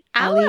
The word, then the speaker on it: Ally: I think it was Ali or Ally. Ally.